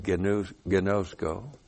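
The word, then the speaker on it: ginosko (γινώσκω): Well, in the Greek, when you have a verb like ginosko, we have ginosko, that's G-I-N long O, S-K long O, or you have pistelwo, and we have that verb here too, P-I-S-T-E-U long O.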